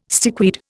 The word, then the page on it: stickweed: Indian